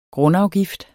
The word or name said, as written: grundafgift